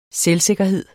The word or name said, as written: selvsikkerhed